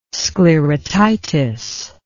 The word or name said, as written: sclerotitis